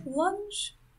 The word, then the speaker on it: Lunge: But, like you said, even though they bring in, like, this Dr. Inspector Lung, is it Lunge? – I pronounce it Lunge. – Yeah, I think it's Lunge.